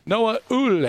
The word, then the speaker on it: Houle: Noah Houle had 2 goals, H-O-U-L-E. How do you say it? – Houle. – Noah Houle had 2 goals for the Lions.